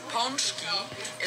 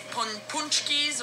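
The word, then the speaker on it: pączki: Pronunciation of the word ponchki is in Polish. Ponchki is many, one ponchek. And you do not say punchkis or punchki, but it's ponchki.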